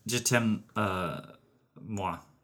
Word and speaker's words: je t'aime moi: How do you say it? – It's just je t'aime moi.